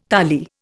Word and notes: tali: Indian

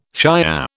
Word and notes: Shiah: British English

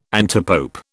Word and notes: antipope: British English

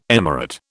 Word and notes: emirate: British English

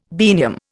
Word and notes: biennium: Indian

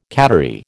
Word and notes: cattery: American English

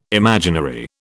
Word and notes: imaginary: British English

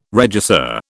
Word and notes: regisseur: British English

